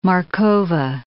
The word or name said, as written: Markova